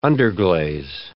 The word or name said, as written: underglaze